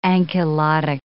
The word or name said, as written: ankylotic